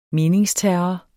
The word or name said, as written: meningsterror